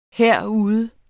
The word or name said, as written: herude